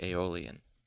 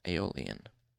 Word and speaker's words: Aeolian: The Aeolian. Okay, it's spelled E-O-L-I-A-N. Aeolian.